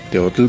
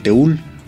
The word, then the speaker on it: teotl: Por ejemplo, la palabra teotl significa Dios. Teotl pronunciado de esa manera y escrito de esa manera. Pero aquí en esta región se le conocía como el teul. Okay. Teul, que también significa Dios.